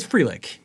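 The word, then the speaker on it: Frelick: Am I saying his name right? – It's Frelich. Yes.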